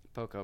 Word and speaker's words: Santo Poco: Santo Poco. Did I say that right? – Santo Poco? – Santo Poco? – Poco. – Poco, very good.